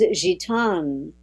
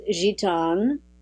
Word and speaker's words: Gitane: Like the letter A, ah, is ah in French. That's why my name is Gitane.